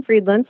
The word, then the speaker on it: Friedland: Yeah, Susan Friedland, spelled like fried land.